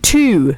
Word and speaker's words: two: The W in the word two is silent, and T-O is two, and T-O-O is also two.